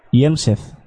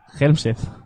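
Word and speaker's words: Hjelmseth: Yelmsted, Que todavía es Yelmsted, Pero todos decimos, todos le llamamos Helmsted, A la portera, me refiero. Que las, las J se pronuncian I.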